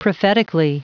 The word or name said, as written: prophetically